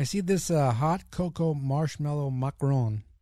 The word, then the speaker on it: macaron: Anyway, he goes macaron. That's how you say it.